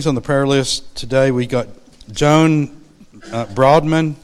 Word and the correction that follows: Boardman: Did I say that right? Where's Diane? Boardman. Okay. I said that wrong.